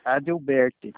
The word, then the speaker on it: Adalberti: É Adalberti, como que se pronuncia? – Adelberti.